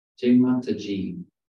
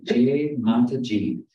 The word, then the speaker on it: Jaimantaji: It's Jaimantaji. – Say it again. – Jaimantaji.